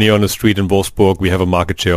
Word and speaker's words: Wolfsburg: Wolfsburg. – Wolfsburg. – Yeah, that's a German way. – Wolfsburg.